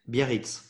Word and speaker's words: Biarritz: I've been to Biarritz. Is that how you pronounce it? – Biarritz in French we say, Yeah, that.